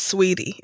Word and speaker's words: Saweetie: It's pronounced Sweetie. It's spelled okay. Saweetie.